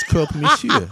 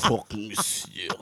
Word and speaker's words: croque monsieur: It's croque monsieur. – It's croque monsieur.